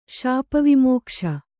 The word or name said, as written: ಶಾಪವಿಮೋಕ್ಷ